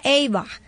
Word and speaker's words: Ava: Ava se escribe así, Ava con B corta, pero se pronuncia Eva.